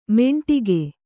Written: ಮೆಂಟಿಗೆ

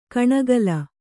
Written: ಕಣೆಕಾಲ್

